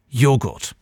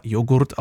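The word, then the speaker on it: yoğurt: Das Wort Joghurt kommt vom türkischen Joghurt, was fast genauso geschrieben wird, aber in etwa Joghurt ausgesprochen wird.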